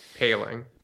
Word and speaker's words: Poling: They opened it up to Ryan Poling, I believe that's how you pronounce that last name. – Paling.